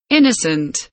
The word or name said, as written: innocent